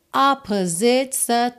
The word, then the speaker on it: opposites: Esse of boy, ele quase não aparece, fica of boy meeting girl, opposites attract. E aqui é interessante reparar que fora da música, né, essa palavra opposites tem a sílaba tônica na primeira sílaba, opposites.